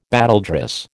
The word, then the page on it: battledress: American English